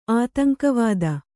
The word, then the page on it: ಆತಂಕವಾದ: ātaŋkavāda